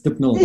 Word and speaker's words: Dippnall: Most people say Dippnall, but it's Dippnall from where I'm